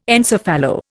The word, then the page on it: encephalo: Indian